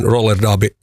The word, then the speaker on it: roller derby: Mut meillä on siis Suomen, kai se on sitten oikein roller derby maajoukkueen päävalmentaja täällä. Tervetuloa, mahtavaa, kun vieraana. Miten se ääntäminen menee oikein näin Suomessa? – Roller derby. – No niin, hyvä.